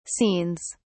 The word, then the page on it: scenes: British English